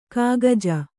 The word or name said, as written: ಖಗಜ